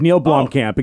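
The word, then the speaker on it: Neill Blomkamp: Yes, and I never can say his name. Do you know how to say it? Neil Blomkamp? – Yeah, exactly. Yeah. Neil Blomkamp, exactly.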